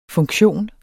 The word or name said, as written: funktion